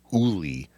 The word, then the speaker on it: Olle: Apparently he kind of changed it from last year, so they settled on Uli, O-L-L-E, Swedish.